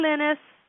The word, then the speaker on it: Lynnis: It is, and just so everybody knows, Linus is spelled L-Y-N-N-I-S, and it's a very I like the name, and I have the middle name spelled like that, so I like that because it's like is Linus.